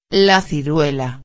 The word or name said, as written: la ciruela